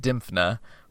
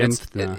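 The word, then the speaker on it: Dymphna: I believe it's pronounced Dimphna, but Dimphna. It's hard to say even if you intellectually know how to pronounce it.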